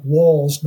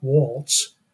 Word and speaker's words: Walz: Second, I think it's fair to say that Walls, and it's pronounced, I'm told, Walls, not Waltz.